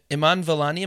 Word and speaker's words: Iman Vellani: Like, even just the actress, Iman Vellani, am I pronouncing that correct? – That's right, yeah.